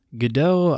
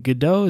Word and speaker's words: Godot: Godot, I, I used to call Godot Godot because I never heard anyone say it out loud, and it's spelt like Godot.